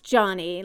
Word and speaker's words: Gianni: G-I-A-N-N-I. I like that. – Yeah, it's pronounced Johnny, like J-O-H-N-N-Y, really.